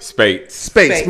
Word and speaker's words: Speights: Spates.